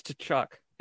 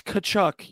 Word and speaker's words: Tkachuk: It's Tkachuk. – It's not. It's Tkachuk. You don't say the T.